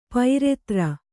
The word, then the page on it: ಪೈರೆತ್ರ: kannada